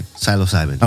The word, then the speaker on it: psilocybin: Psilocybin. – Psilocybin.